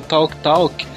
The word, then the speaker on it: Talk Talk: Talk. Talk, quem é? – Talk. – Life's What You Make It, que toca na rádio Flash FM, que eu indiquei aqui. – Ah, tá ótima a pronúncia.